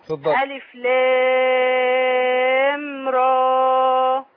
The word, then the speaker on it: را: ألف لام را. – لا أقول ألف لام را. – را را را بس مفيش همزة.